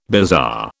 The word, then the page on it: bizarre: British English